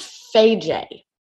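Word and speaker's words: Fage: I think it's pronounced Faye.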